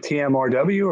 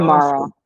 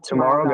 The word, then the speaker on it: TMRW: do you say it TMRW or do you pronounce it Tomorrow Golf? – Tomorrow. Yeah, Tomorrow Golf.